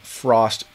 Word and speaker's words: FRXST: And the way these different grades work is that each of them have a different multiplier of Frost token, okay? F-R-X-S-T. It used to be, they were going to call it F-R-O-S-T, but I think there were other Frost tokens, so they changed it, but it's still pronounced Frost.